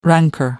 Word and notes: rancor: British English

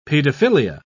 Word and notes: pedophilia: British English

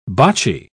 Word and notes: botchy: British English

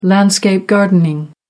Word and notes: landscape-gardening: American English

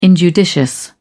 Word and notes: injudicious: British English

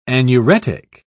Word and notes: anuretic: British English

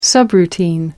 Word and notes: subroutine: American English

